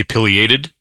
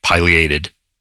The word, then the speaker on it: pileated: But I say pileated, and I just learned or maybe relearned that that's not correct. You know, some things like plover, clover. – Oh, I'm glad I said it right. – Yeah, you said it right. And I looked it up, and it comes from a Latin word, pileus, meaning cap.